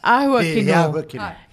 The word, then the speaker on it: ahua kino: Yeah, he a tahua kino, like the, oh, sorry, bad beautiful. ahua kino. He ahua kino.